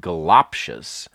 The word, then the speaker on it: goloptious: And uh, goloptious? It's the lop, the second syllable. And you guessed it, the other two are schwa's.